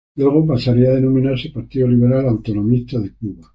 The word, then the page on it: denominarse: spanisch